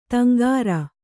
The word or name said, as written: ದೊಂಗರ